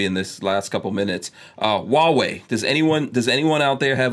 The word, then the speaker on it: Huawei: It's pronounced Huawei, but it...